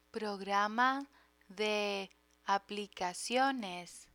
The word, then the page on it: programa de aplicaciones: Spanish